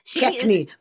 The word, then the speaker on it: McKechnie: McKechnie, but that's okay.